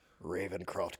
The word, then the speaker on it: Ravencroft: Not Ravencraft crazy? – Ravencroft crazy. Yeah, it doesn't have the same ring to it. – Yeah, I appreciate you pronouncing it correctly.